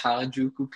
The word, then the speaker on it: Harajuku: Vil du hellere klæde dig ud som en traditionel geisha eller en ha, det er my pronunciation. Harajuku-pige. I don't know if that's how you say it. Sorry. – Ja, det lyder fint. Jeg vælger Harajuku Pi, fordi ja, det er lidt nemmere måske, og jeg føler lidt mere komfortabel.